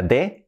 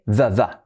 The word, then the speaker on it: the: But the very next day, vamos a analizar la pronunciación, but the, que no sea de, the. La TH, very, esa palabra, yo tenía muchos problemas con esa palabra, very, que no sea very, no.